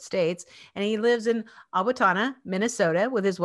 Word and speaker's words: Owatonna: Yeah, good job on the name pronunciation. My dad, who lives in Utah, still calls it Owatonna. So you did well.